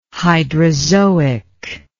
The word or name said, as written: hydrazoic